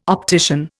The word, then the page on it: optician: Indian